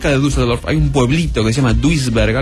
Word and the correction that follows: Duisburg: algo así, donde apareció otro señor y me invitó a su casa también. Pero este es peor en realidad que el anterior. Esto es Duisburg.